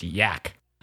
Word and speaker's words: gnac: And you also managed to coin a word pronounced yak that's spelled G-N-A-C.